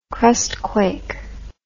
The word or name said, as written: crustquake